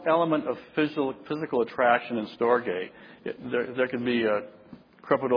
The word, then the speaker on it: storge: The first one is not storage, it's pronounced storge.